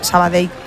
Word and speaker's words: Sabadell: Sabadell. A mí me lo decían mucho cuando estaba en Primera Federación, porque yo también decía Sabadell, que es como se dice.